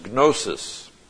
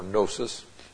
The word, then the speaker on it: gnosis: Now, the Greek noun translated knowledge is gnosis, or gnosis. The G is silent.